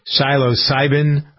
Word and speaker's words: psilocybin: They voted to decriminalize, I got to pronounce this correctly, psilocybin. I believe that's how you pronounce it.